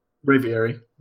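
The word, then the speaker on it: Braviary: I also finished my Ultra Sun with Breviary, and that's how you pronounce it? – Breviary. – Breviary.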